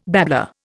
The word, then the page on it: babbler: Indian